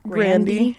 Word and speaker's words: Grande: Isn't Ariana Grande's name pronounced Ariana Grande? It's Grande. Yeah, it really is.